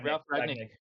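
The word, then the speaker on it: Ralf Rangnick: Yeah, Ralph Ragnick.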